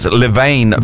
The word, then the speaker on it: Levain: Levin Bakery in Blockhouse Bay. I think I said that wrong. I think it's Levain Bakery in Blockhouse Bay, Auckland.